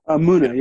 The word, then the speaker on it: Muna: Let me ask you about Muna, right? Is that how you say it? – Muna.